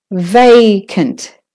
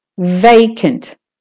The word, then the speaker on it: vacant: Vacant. So the va is the stressed syllable. Vacant.